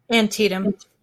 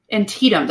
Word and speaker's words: Antietam: clearly, though, today's depictions of battlefield scenes, the attack on the sunken road and how do you say that word again? – Antietam. – Antietam, that's right.